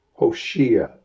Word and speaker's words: Hoshea: Hoshea, it's like the word Joshua without the initial syllable, which means just liberator.